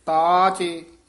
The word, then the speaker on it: ताचे: ये पाठ ताचे इस तरह उच्चारण करना, ताचे इकट्ठा भी नहीं कह देना, ना ता चे वखरा करना, यानी डैश ला के विच लमका के बोलना, पाठ इकट्ठा ही है उंज, लमका के इसका उच्चारण करना,